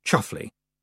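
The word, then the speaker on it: Chumsfanleigh: Roland de Chums Fanley, pronounced Chuffley, it wasn't his fault, carefully paid it no attention.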